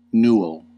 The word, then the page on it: newel: American English